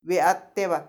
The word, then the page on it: behateba: Macuiltianguis